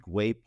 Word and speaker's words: Güell: And we were saying Güell Park, that's how you pronounce it, but it's spelled G-U-E-L-L. So until we really found out that it was Güell, we were calling it like Güell Park ourselves. So if you're going to Google it to see photos, G-U-E-L-L, Güell Park.